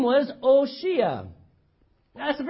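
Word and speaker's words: Hoshea: It's actually pronounced O'Shea.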